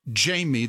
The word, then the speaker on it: Jamy: Or you can email me at Jamie, that's spelled J-A-M-Y, at business-academics, academics is plural, .com.